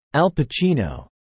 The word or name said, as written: Al Pacino